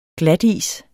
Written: glatis

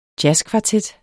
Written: jazzkvartet

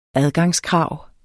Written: Adgangskrav

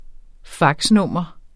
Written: faxnummer

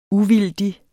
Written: uvildig

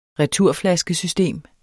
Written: returflaskesystem

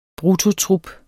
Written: bruttotrup

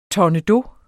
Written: tournedos

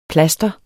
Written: plaster

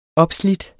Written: opslidt

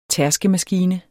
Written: tærskemaskine

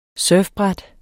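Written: surfbræt

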